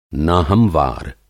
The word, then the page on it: नाहमवार: نا ہموار; ना-हमवार